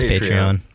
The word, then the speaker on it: Patreon: As you can tell, by the way, I can't decide how to pronounce Patreon. Patreon? Patreon? – It's Patreon.